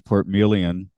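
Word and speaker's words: Portmeirion: I did learn immediately it's Port Melian, I guess, unless he's saying it wrong, but I feel like I should trust him on that.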